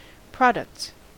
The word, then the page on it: products: American English